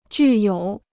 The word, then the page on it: 具有: jù yǒu